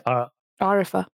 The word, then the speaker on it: Arifah: So wait, your full name is Arifa. – Arifa. – Mm-hmm. – Okay, but it's spelled like A-R-I-F-A. – A-R-I-F-A-H.